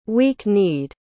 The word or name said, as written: weak-kneed